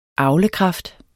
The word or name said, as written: avlekraft